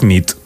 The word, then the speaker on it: nit: Y noche es nit.